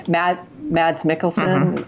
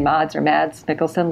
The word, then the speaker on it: Mads Mikkelsen: Mads Mikkelsen, that's how you say his name, Mads Mikkelsen, looks absolutely amazing.